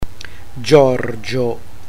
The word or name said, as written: giorgio